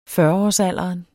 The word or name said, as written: fyrreårsalderen